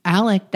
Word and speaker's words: Alex: Also, interesting thing, the patriarch, that's sort of like the living patriarch, Alex Murdaw, his name is pronounced Illich. – Really? – Well, that's what she said. – Is it spelled Alex? – It's spelled Alex. – And it's pronounced Illich? – Or Alec.